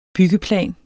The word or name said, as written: byggeplan